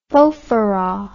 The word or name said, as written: fofarraw